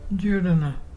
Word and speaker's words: Jurruna: Djurdjana. Djurdjana is pelican. And to spell it is J-U-R-R-U-N-A.